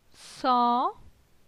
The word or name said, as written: San